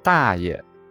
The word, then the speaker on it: 大爷: 一，大大爷是对父亲的哥哥的称谓，爷字读轻声，无音标。